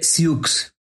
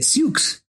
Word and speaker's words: Sioux: Se escribe Sioux y mucha gente equivocadamente les dice Sioux, pero la pronunciación correcta es Sioux.